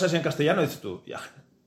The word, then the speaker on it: Hiagen: El nombre concreto es Jagen.